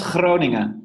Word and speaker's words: Groningen: Yeah, it's pronounced Groningen, which is with a very hard G, which is almost impossible to learn when you're a native English speaker.